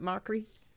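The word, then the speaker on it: Mochrie: Is it Mockery? Do I say it wrong? – You got it.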